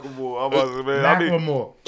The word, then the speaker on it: Macklemore: I said his name wrong? – A little bit. – Macklemore.